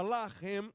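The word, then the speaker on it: lahem (לחם): Quando alguém que fala o hebraico fala uh, ah, laham, significa luta, mas com a mesma escrita, se ele falar lahem, significa pão. Uau!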